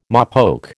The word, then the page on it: mopoke: American English